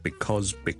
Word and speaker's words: because: Because 呢个字个发音，好多时候咧，我哋系唔会读成好完整嘅，我哋正确啦，because 我哋系 because 系 b 啦，第一个 vowel 系 a 啦，第二个 vowel 系 o 跟住后面系一个 z 音 because，但系咧喺口语嘅时候咧，尤其是当呢个字唔系咁紧要嘅时候咧，我哋可能会读得好快好快，大家都可能会见过 coz 呢个简写，ok。